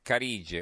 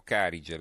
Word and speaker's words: Carige: Veniamo invece ai titoli sulle banche, ce ne sono molti, alcuni ci aprono, tanto per cominciare, il secolo XIX, eh, Carige o Carige, le viene pronunciate in entrambi i modi,